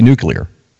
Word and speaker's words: nuclear: It's going to be nuclear. – Okay, but my question is pronounced nuclear.